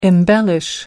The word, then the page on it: embellish: American English